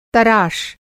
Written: तराश

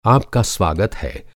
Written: स्वागत

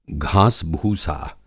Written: घासभूसा

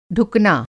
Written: ढुकना

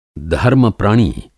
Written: धर्म-प्रणी

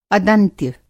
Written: अदंत्य

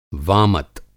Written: वामत

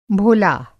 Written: भोला

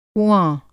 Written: कुआँ

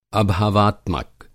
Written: अभावात्मक

